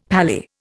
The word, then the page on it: pally: Indian